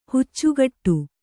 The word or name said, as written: ಹುಚ್ಚುಗಟ್ಟು